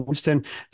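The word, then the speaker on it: Wynston: And the upside of that is if you type in Michael Winston,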